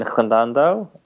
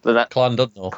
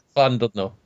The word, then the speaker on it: Llandudno: And then we've got Sci-Fi Wales in September in Llandandow. That's probably pronounced wrong in a Welsh. – I would probably pronounce it Llandudno. – Llandandow. – Llandudno.